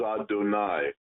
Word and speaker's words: Adonai: The Hebrew word is Adonai.